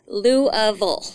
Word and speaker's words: Louisville: Now you got to say it right. You said it right. I did not. – Yeah. Most people in this area say Louisville. – Louisville. I know it's Louisville.